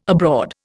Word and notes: abroad: Indian

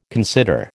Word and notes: consider: American English

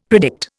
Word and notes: predict: Indian

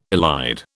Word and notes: elide: British English

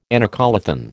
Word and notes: anacoluthon: American English

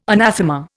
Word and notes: anathema: Indian

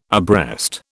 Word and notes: abreast: British English

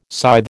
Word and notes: scythe: American English

